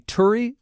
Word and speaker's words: Turi: Apple is paying $200 million for this Seattle company, Turi. Am I saying it right? – That's right, yes.